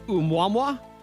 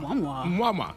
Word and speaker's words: Oumuamua: And then you tell me what you think it what the pronunciation would be. Okay. O-U-M. – O-U-M. – U-A-M. – U-A-M. – U-A. – U-A. – Oumuamua. – Okay. – Right? – Oumuamua. – Oumuamua. – Like I'm kissing.